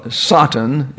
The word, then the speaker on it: Satan: Satan is actually the first syllable that's emphasized.